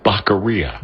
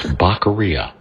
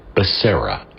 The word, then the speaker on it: Becerra: It's Baccaria. Biden taught us this. It's Baccaria, not Becerra.